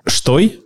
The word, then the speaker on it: Støy: Но «штой», а читается изначально как «штой», перевели просто «стой». Просто «стой».